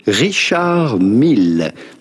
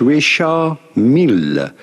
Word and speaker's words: Richard Mille: are looking at how to pronounce the name of this famous luxury Swiss watchmaker and car racing team, Richard Mille. Do not pronounce the D to Richard. It's not like in English, you do not say Richard, but rather Richard. In English, Richard Mille to pronounce the double L.